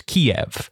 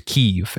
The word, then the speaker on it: Kyiv: I had one question here, one distinction that I wanted to draw, because I grew up pronouncing the name of Ukraine's capital as Kiev, but I recently learned that the preferred Ukrainian pronunciation is Kyiv.